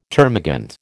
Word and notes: termagant: American English

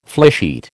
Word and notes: flysheet: American English

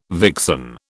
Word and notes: vixen: British English

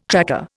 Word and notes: tracker: Indian

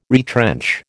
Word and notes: retrench: American English

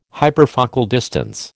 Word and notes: hyperfocal distance: American English

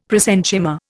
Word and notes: prosenchyma: Indian

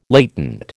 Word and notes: latent: American English

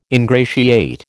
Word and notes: ingratiate: American English

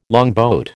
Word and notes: longboat: American English

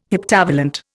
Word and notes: heptavalent: Indian

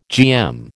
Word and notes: GM: American English

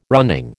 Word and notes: running: American English

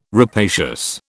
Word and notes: rapacious: British English

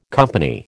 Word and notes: company: American English

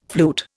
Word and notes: flute: Indian

